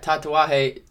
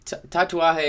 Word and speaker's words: Tatuaje: Yeah, Tatuaje. Tatuaje is the brand.